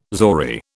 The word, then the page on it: zori: British English